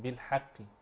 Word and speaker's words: بالحق: الصحيح أن ننطق بترقيق الحاء وتفخيم القاف تفخيما نسبيا بالحق.